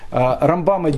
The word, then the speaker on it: Рамбам: Э, поэтому э, считается в религиозном мире, как, э, как принято, Рамбама делает на первое ударение, что было писано, а Рамбана делает на второе, чтобы в быстрой речи понять, о ком идет речь.